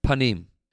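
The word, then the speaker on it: panim (פנים): How do you say the word face in Hebrew? Panim.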